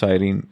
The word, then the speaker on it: Cyrene: I think it's pronounced Cyrene, C-Y-R-E-N-E.